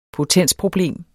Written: potensproblem

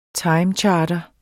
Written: timecharter

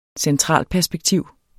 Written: centralperspektiv

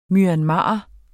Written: MYANMARER

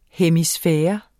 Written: hemisfære

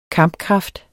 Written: kampkraft

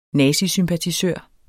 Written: nazisympatisør

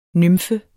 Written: nymfe

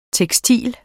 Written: tekstil

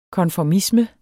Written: konformisme

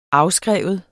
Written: afskriver

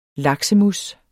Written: laksemousse

